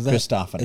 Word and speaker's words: Christophany: A Christophany.